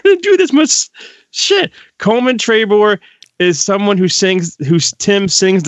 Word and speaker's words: Coleman Trebor: Coleman Trabor. C O L E M A N T R E B O R. – Correct. Yeah. – Coleman Trabor.